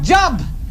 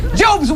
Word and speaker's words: Job: Pronounced Job and not Job.